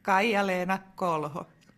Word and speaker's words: Kaija-Leena Kolho: Hi all, I'm Kaija-Leena Kolho. That's how we pronounce it.